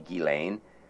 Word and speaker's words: Ghislaine: She was his favorite child, and he named his yacht that he was assassinated on, Lady Ghislaine. I'm using the French pronunciation of the name. We'll hear it pronounced in a variety of ways.